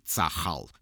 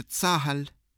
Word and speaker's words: Цахал: Ну, то есть это он по-русски Цахал, с твердым «ха» и ударением на последний слог. А на иврите, конечно, говорят иначе Цахаль.